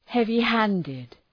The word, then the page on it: heavy-handed: ,hevı’hændıd